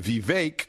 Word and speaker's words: Vivek: But here's an interesting clip I want to play to you from Vivek, because that's how it's pronounced. It rhymes with cake, according to him.